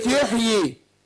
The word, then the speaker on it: يحيي: خد بالك كلمة يحيي، يحيي ويميت. احنا عندنا في ياءين، احنا يعني هي مرسومة ياء يحيي. كلمة يحيي هنا مرسومة بياء واحدة لكن هي بتنطق ياءين.